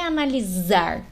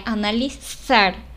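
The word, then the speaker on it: analizar: Como, por exemplo, a palavra analisar se escreve com Z no espanhol e não é analisar, como no português, que a gente faz esse som de z, é analisar. Então, é mais parecido como se fosse com um C cedilha.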